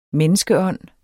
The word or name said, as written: menneskeånd